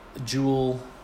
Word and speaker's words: JUUL: Topic for this week is JUUL e-cigarettes.